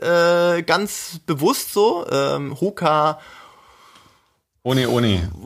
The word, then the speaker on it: Hoka One One: Es wird halt hier äh, Hoka One One äh, ausgesprochen, weil äh, die hawaiianische, das ist ein hawaiianisches Sprichwort, äh, Hoka ohne, ohne.